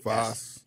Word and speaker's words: Faas: How would you pronounce F-A-A-S? F-A-A-S. – Foss. – Foss, okay.